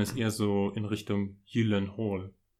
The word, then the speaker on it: Gyllenhaal: Naja, es ist ja ursprünglich ein schwedischer Name, aber er ist ja Amerikaner. Wie spricht man es richtig aus? – Als, naja, er, als er, selber, er selber spricht es Gyllenhaal aus, weil in Amerika niemand Schwedisch spricht.